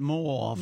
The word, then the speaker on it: mauve: And by the way, you do pronounce it mauve.